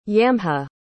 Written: yamha

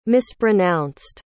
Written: mispronounced

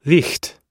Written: licht

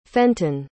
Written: fenton

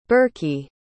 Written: birkie

